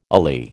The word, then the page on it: alee: American English